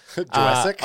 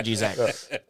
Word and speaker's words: Juszczyk: Although, do I hope and agree that like that's the idea is moving towards where how do you say it? – Juszczyk is. – Juszczyk? – Oh, I mean, look, there's like fucking five consonants in a row.